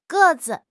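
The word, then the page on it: ge4 zi5: tone 4; tone 5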